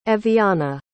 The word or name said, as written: Eviana